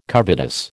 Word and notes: covetous: American English

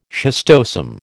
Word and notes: schistosome: American English

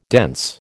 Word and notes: dense: American English

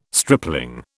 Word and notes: stripling: British English

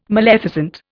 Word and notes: maleficent: Indian

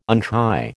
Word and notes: untie: American English

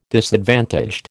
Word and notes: disadvantaged: American English

